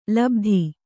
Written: ಲುಬ್ಧ

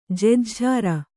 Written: ಜೆಜ್ಝಾರ

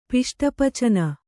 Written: ಪಿಷ್ಟಪಚನ

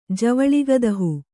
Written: ಜವಳಿಗದಹು

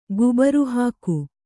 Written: ಗುಬುರುಹಾಕು

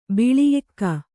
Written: ಬಿಳಿಎಕ್ಕ

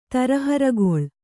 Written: ತರಹರಗೊಳ್

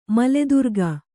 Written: ಮಲೆದುರ್ಗ

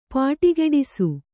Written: ಪುಡಿಗುಟ್ಟಿಸು

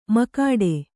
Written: ಮುಕ್ಕೋಟಿ

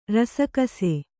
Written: ರಸಕಸೆ

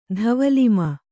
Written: ಧವಲಿಮ

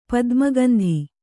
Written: ಪದ್ಮಗಂಧಿ

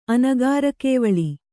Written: ಅನಗಾರಕೇವಳಿ

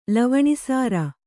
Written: ಲವಣಿಸಾರ

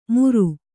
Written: ಮೂರು

